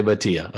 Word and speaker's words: Bhatia: All right, let's make sure Akshay, you get it. Akshay Bhatia. – Akshay Bhatia, okay. – Not Bhatia. Right.